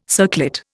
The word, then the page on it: circlet: Indian